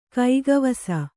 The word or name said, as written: ಕೈಗವಸ